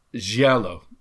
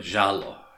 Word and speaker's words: giallo: I think this movie is counted as being part of a sort of film school, which I think is referred to as giallo. I think that's the Italian pronunciation of it. – Giallo.